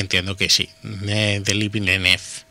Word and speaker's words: Villeneuve: Una película dirigida por Denis Villeneuve, Villeneuve, ¿lo he dicho bien? – Sí, sí, bien.